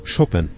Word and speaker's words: Chopin: Das Haus von Mikołaj und Justyna Chopin, oder Chopin, die Aussprache in Polnisch klingt eigentlich so.